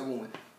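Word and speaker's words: Böhme: una novelista que se llama Margaret Boomer se escribe poemes tengo entendido que se pronuncia Boomer.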